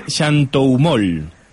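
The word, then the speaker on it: xanthohumol: A ver si lo digo bien, del xantoumol. – Muy bien, perfecto.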